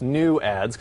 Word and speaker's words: NUADS: And they're actually, it's pronounced new ads because there's a new form of advertisement that's coming to Kinect as early as spring 2012.